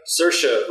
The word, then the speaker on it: Saoirse: Saoirse Ronan in Hannah.